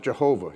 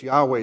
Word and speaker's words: Yahweh: Best guess by Hebrew scholars today is Yahweh is the right way to pronounce it.